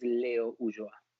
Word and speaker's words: Ulloa: The correct pronunciation is Leo Ulloa.